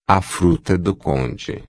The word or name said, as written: fruta-do-conde